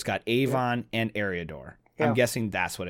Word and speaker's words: Eriador: The narrator, I don't know if he just got his copy all in all caps or something, but he doesn't pronounce Eriador as Eriador. He pronounced it as Erlador.